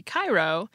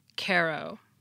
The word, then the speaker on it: Cairo: And an Illinois town that's spelled like the Egyptian capital city Cairo, but is pronounced Cairo.